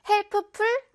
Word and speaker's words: helpful: Helpful 아니겠죠? Helpful. Helpful. P랑 F랑 같이 있어서요. 조금 어려울 수 있는 단어니까 발음 주의를 해야겠어요.